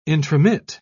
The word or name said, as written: intromit